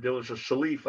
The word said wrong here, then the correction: Caliph: am I saying that right? Salif, a Caliph, I think it's Caliph.